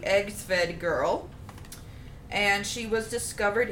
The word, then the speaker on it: Egtved: So she is spelled EGTVED. – One more time, girl. – EGTVED. Egsved girl.